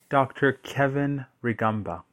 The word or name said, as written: Dr. Kevin Rwigamba